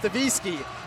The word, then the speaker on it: DeWicki: Their starting lineup, Naomi White, Elena Tia, Cambry Rodriguez, Whitney Davisky, it's spelled DeWicki, but it's Davisky, Emerson Matthews, Audrey Hollis, and Carissa Mormon.